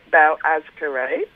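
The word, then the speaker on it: Boğazkere: Like, how does Well, if it's brand new to Australia, the Turkish grape, Bau Azkere, is the pronunciation.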